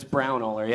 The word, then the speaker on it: Braunohler: It's Braunohler, yeah. – I said it right that time. – Yeah, you did. It's very German.